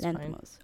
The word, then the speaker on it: Lanthimos: That doesn't sound wrong to me. That's Lanthimos. Fine.